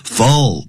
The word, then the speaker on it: full: Full 不要念成 full 啊，full 舌尖抵上去。有两个 L